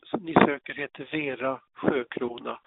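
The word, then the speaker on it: Siökrona: Jag tror man uttalar det sjö, men stavningen är S-I-Ö och sen krona. Ja, men vi bestämmer det nu att hon uttalas Vera Sjökrona helt enkelt.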